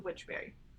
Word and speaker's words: Wychbury: Let me spell that for you, because it's not what you think. It's Wychbury. So Wychbury.